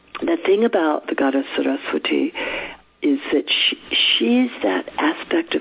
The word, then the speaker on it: Saraswati: And the goddess Saraswati, who's one of my favorite goddesses, her name is sometimes pronounced, as Chris was saying to me, Saraswati.